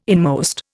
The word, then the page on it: inmost: Indian